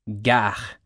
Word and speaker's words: gare: Gare, pronounced with an ah sound.